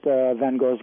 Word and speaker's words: Van Gogh: And I stayed there, and I went, later on, I went to Paris to visit Van Gogh's grave. – Mm-hmm. – Van Gogh, actually, is the proper pronunciation.